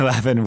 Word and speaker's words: Lavin: So here is TJ Lavin. I am saying that right, right? – Yeah. – Lavin.